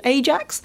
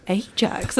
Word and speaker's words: Ajax: And when I was first watching football when I was a kid, I thought, Ajax? And then I remember hearing, all right, oh, it's Ajax. Okay, that's how they pronounce it.